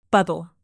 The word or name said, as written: bubble